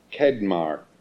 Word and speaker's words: Qedmah: But it's Kedmar, Q-E-D-M-A-H. It's one of those Q words that doesn't have a U, but you can't use it in Scrabble. Kedma.